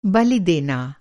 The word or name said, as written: बलि